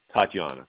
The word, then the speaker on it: Tatjana: TATJANA. Tatiana. That's how you say it.